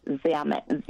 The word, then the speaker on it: Zammit: Father Joseph Zammit, Z-A-M-M-I-T, Zammit.